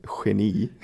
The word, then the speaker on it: Genie: Und dann gibt es ja halt noch das G, spricht man ja auch ganz oft, wie so ein Sch laut aus. – Ja, genau, in manchen Beispielen, zum Beispiel hier bei Genie, das Genie.